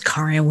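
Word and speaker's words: Karin: I am your host, Karen Weary.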